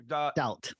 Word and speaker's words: Staudt: Staudt.